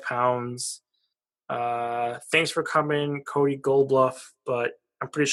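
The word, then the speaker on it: Goloubef: Cody Golubov is how you pronounce it on Elite Prospects.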